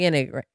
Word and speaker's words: Enneagram: But we're doing a little personality quiz, a little personality test, specifically the Enneagram. – Enneagram. – Before this, we were like, how do you say it? Enneagram? But it's Enneagram.